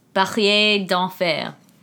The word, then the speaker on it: Barrière d'Enfer: I mean, yeah, but how do I pronounce it in French? – Oh! Barrière d'Enfer.